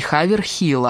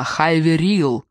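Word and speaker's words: Haverhill: Не Хаверхилл, а Хайверилл. Откуда ей знать, как произносится этот город, где живет ее семья, где вместе выросли ее братья и сестра? Этот город, о котором она впервые услышала 2 недели назад, нет. Она не знала про Хэ.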